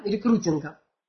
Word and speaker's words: рекрутинга: Рекрутинга, точнее, рекрутинга. Ударение на «у».